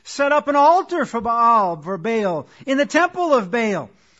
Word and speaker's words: Baal: He went and served Baal, or Baal as it technically is pronounced, and worshipped him.